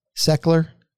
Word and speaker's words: Sechler: Sechler? – You sure did.